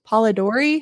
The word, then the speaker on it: Polidori: So they have her, and then they meet up with Lord Byron and John Polidori.